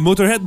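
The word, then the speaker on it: Motörhead: Motorhead, drôle d'affaire ça. Des accents, des trémas sur les deux O, mais ça change pas du tout la prononciation.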